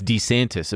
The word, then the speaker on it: DeSantis: By the way, it's DeSantis, apparently. That's how he pronounced it himself, his own name.